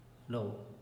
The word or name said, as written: lo